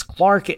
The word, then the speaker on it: Clerk: Apparently, the middle name, it reads as Clerk, but it could be pronounced as Clark. That's Scottish for you.